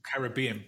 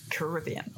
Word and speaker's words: Caribbean: Can I just say you pronounced the Caribbean perfectly well. I was just joking, really, because, there's two accepted pronunciations, isn't there? And I can't do the flashy one that you do. – Caribbean.